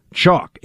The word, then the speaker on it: Choq: Chalk. It's spelled C-H-O-Q.